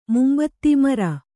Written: ಮುಂಬೆದೆ

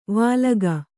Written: ವಾಲಗ

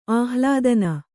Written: ಆಹ್ಲಾದನ

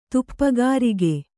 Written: ತುಪ್ಪಗಾರಿಗೆ

